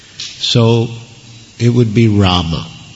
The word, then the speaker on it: Rama: But in terms of proper pronunciation, it should be there. Sanskrit. I mean, there's Bengali pronunciations and Hindi pronunciations, but Sanskrit, it would be Rama, Hare Rama.